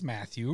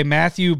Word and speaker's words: Mathieu: And then this is the one where you need the button ready, but it's spelled, I think it's Matthew, but it's spelled M-A-T-H-I-E-U. I like that because I think it's Matthew, right? – Yeah, that'd be like Tyrann Mathieu from the NFL.